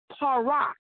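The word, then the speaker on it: parach: And in Hebrew, the word for fly, you can find in Strong's Concordance 6524, which is parak. It's pronounced, it's P-A-R-A-C-H, but it's pronounced parak.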